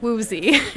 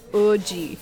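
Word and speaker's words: Woozi: I would pronounce it Woozy. It's W-O-O-Z-I from 17. – Mm-hmm. – The actual way you pronounce his name is O-G.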